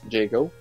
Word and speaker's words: Jago: avec la thème, la chanson thème de Yago. – Jago. – Ben, Jago, mais en même temps, le J se prononce Y en anglais, mais je sais pas si c'est ça.